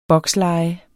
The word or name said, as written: boksleje